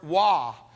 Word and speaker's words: waw: And the word "and" in Hebrew is the word "waw." W-A-W is how you would spell it in English, and roughly how it's pronounced.